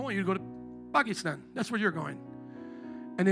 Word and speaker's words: Pakistan: You got to say it like how they say it. It's not Pakistan or Pakistan. It's Pakistan. Yeah, Pakistan. God, I'm not sure about this trip now.